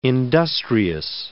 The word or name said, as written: industrious